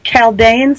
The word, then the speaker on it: Chaldean: One of them is called the Western Pythagorean system, and the other is the Chaldean system. I hope I'm pronouncing that correctly. – That's correct, yeah.